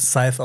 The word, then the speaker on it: Scythe: das wird Scythe ausgesprochen, hat auch jemand in den Kommentaren geschrieben.